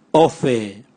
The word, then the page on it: ove: Raga